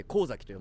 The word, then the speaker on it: 神崎: 漢字で神様に先、岬の先、神崎と書いて神崎と読むんです。